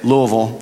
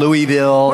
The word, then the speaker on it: Louisville: And you can tell that we lived there because I know how to say it, Louisville. They train you when you move there. They tell you how to say the word. It's not Louisville. It's not Louisville.